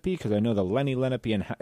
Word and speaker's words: Lenape: Okay, the Lenape, I've heard pronounced Lenape as well, is we are considered to be the grandfather nation of this particular area.